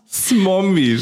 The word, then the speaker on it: Smombie: Ich kann das gar nicht aussprechen. – Smombie, Schatz. – Smom, oder? Smom? – Ja, oder Smombie. – Smombie. – Ja, genau.